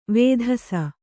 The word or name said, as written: ವೇಧಸ